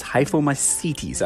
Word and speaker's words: hyphomycetes: The fungi, known as aquatic hyphomycetes, which, boy, did I butcher that name. It's hyphomycetes, actually. I keep pronouncing it. I always say that the wrong way.